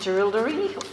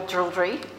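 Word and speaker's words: Jerilderie: I was born and raised in Dooraldri, or Dooraldri, if you live in Dooraldri, you can say it like that.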